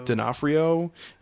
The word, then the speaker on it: D'Onofrio: Also, other major people include Ty Simpkins, who was the kid from Iron Man 3, Vincent D'Onofrio, is that how you say his name? – D'Onofrio.